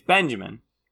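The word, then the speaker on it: Binjimen: Yes, it spells B-I-N. – It's Benjamin, but it's spelled Benjamin Victor from Ohio State.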